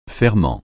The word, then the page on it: ferment: francais